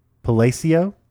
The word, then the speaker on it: Palacio: It is the Palacio, which is Palace. I'm not sure if I'm pronouncing that correctly. Del Inca in Cusco. – That's right.